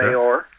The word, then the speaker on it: Sotomayor: Here, Rush Limbaugh starts talking about this judge, who's a Supreme Court judge, can't pronounce her name, Sotomayor or something like that. – Sotomayor.